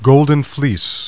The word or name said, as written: gold